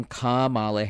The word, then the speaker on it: Kamala: Kamala Harris, that's how you say her name. Kamala Harris, Ka, as in Macaw.